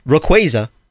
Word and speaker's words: Rayquaza: Dude, I've pronounced Rayquaza correctly. Rayquaza. Rayquaza is how you pronounce Rayquaza.